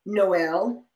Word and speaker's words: Noelle: And I'll spell it because I know it's a very uncommon name, but it's JATAUN- take your pick, Noel with two L's and an E.